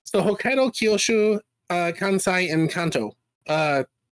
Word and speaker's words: Kanto: So Kanto is literally Kanto. It's got like a long O symbol over it.